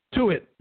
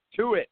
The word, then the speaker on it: Tuitt: First off, how do you say it, Tuitt or Tuitt? – Tuitt. – To it, okay. – Two T's makes it two it.